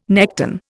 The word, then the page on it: nekton: Indian